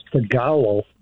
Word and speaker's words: Tagalog: Oh, it's pronounced Tagalog.